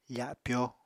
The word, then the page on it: fapyo: apatani